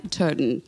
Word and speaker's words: Turton: We are now joined with Stuart, and in my American accent, I am trying to not butcher the last name, Turton. How to do, Stuart? Is that better? – That's amazing.